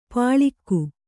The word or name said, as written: ಪಳಿಗೆ